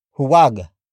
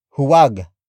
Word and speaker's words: huwag: Huwag. Huwag. Huwag. Huwag is spelled as H U-W-A-G.